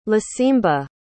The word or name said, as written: lisimba